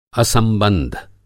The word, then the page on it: असंबंद्ध: Hindi